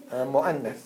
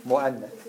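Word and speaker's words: Mu'annath (مؤنث): Mu'annath. Yeah, you wrote it right. Mu'annath.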